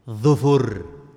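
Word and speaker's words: Dhufur (ظفر): Nah, dhufr ada dua bahasa. Dha yang didhammahkan dan fa yang disukunkan. Bacanya gimana? Dhufrun. Ini bahasa. Bahasa yang lain, dha dan fa kedua-duanya didhammah. Bacanya apa? Dhufur. Dan dua bahasa ini sah. Bahkan yang kedua yang lebih baik. Dhu, dhufur. Dua-duanya didhammahkan.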